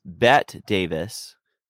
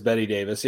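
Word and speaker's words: Bette Davis: But it's also is it Betty Davis or is it Bet Davis? – It's spelled like Bet, but it's Betty. They say Betty. – Yeah. Oh, they say Betty? Okay. – Yeah, it's always Betty Davis.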